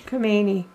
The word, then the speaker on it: Khomeini: Khomeini.